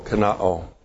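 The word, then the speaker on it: kenoō: K-E-N-O long O, kenao.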